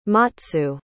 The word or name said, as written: matsu